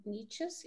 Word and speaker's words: niches: Niches, is that how you say it? Niches. – Yeah.